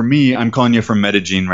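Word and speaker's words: Medellín: Anytime you have a double L in this region, in this accent of the people that live here, they pronounce it as a J sound rather than an L, like a Y sound, like okay. In classic Spanish. So they call it Medellin.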